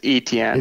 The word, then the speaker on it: Etienne: I've seen it pronounced Travis Achin. I've seen it. I've heard it pronounced Travis Etn. Um, so I believe it's Etn.